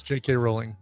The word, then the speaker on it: Rowling: I heard it was Rowling and not Rowling. Rowling's. We've been saying it wrong. – Yeah, it's J.K. Rowling. Because the old joke was, how does Harry Potter get around? And you say, I don't know, walking, J.K. Rowling.